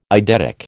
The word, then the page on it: eidetic: American English